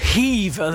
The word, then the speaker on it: Hever: Very good Hever there. – Yes, you have to have the Hever.